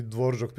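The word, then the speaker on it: Dvořák: se i Dvoržak piše Dvorak, tako mm da mhm. ja to tako izgovaram, da, da, pa prosto, da.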